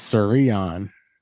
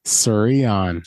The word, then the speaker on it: Creon: Your middle name is Creon. – It's Sirion. – Say it again. – Sirion. – Sirion. It's spelled C-R-E-O-N. Sirion.